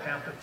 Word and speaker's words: Pampa: She's been putting up with me for 52 years, and we started out in a place called Pampa, Texas. That's spelled with a P, not a T. Everybody here, I'm saying Pampa, oh, and that's in Florida, no, in Texas, in the panhandle of Texas.